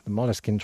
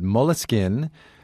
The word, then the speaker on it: Moleskine: Having said that, though, Moleskine as a word is originally an English word. So the English pronunciation is Moleskine.